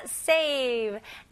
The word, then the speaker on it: save: 여기서는 V 발음 명확히 하셔야 됩니다. save 이런 식으로 하시면 안 돼요. V 발음은 이렇게요. save. Okay, save. 네, 이가 밑에 입술을 닿아야 됩니다.